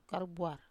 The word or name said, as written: karubuar